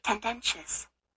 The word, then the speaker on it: tendentious: Tendentious. – Oh, it's tendentious. And that's the right way to pronounce it.